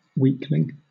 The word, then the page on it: weakling: British English